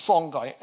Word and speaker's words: Songhai: And then you've got the third empire in the same region, just bigger, called the Songhai Empire. Now, Songhai is how we say it in English. Some people think that should be pronounced Songhai, but we'll just keep it pronounced. We'll keep the pronunciation simple.